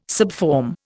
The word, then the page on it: subform: Indian